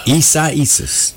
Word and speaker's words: Isaias: I think that's right. So that's how I've heard it pronounced many times. Isaias.